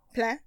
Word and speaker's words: Plein: Isn't Plein not pronounced like that, but spelled the same way? Rain in French? Plein? – Plein? Yes, it is. With the nasal sound on the end? Yeah. Plein. I'm sure in Swiss, Plein. I'm sure in French Swiss, he probably pronounces it like that.